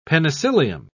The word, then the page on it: penicillium: British English